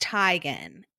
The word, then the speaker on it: Teigen: So she had said, actually, it's pronounced Teigen.